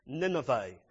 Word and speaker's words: Nineveh: The capital of ancient Assyria for a long period of its time was this place, Nineveh. Please get the pronunciation right. V-E-H, Nineveh. Not Nineveh or anything else. People think you've invented a new place, but that doesn't matter. Nineveh.